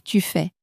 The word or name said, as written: tu fais